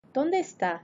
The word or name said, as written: donde esta